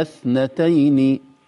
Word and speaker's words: اثنتين: أمتنا اثنتين مباشرة إلى الثاء، وأحييتنا اثنتين مباشرة، ما في ألف أبدا في النطق.